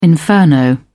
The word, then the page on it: inferno: British English